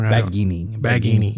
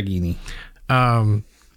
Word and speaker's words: Baggini: The author of the book is Julian Seguini. Or I may be saying that wrong. – Baggini. Yep. Julian okay. Baggini.